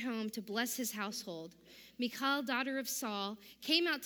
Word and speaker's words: Michal: How do you pronounce the wife of David? Is it Michal? Okay, thank you, Hebrew scholar.